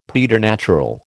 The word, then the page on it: preternatural: American English